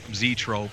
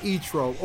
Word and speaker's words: Zoetrope: It's pronounced Etro. But it's and the only reason I know that is because that show, The Metal Shop, because I used to always tell everybody, you ever heard of Zoetrope? And that's how I always tell everybody. But it's actually pronounced Etrope.